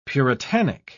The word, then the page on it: puritanic: British English